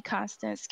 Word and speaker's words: Konstance: Um, uh, you can find me on Psychology Today, Konstance Castle, last name spelled C A S T L E, first name spelled with a K.